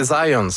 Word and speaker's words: Zajonc: Vous avez dit plus Zajonc, mais... – Ah, parce que j'ai prononcé un petit peu à l'anglais. J'ai prononcé mon polonais à l'anglais.